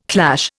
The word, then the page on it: clash: Indian